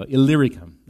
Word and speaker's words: Illyricum: Illyricum.